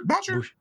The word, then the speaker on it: Boucher: Boucher? – Boucher. You got to say Bobby Boucher accent.